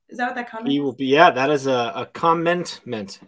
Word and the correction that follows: commitment: Is that commentment instead of commitment? Am I saying that right? Commitment? – Yes.